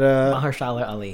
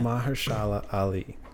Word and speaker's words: Mahershala Ali: But Mahershala Ali, yeah. – Mahershala Ali.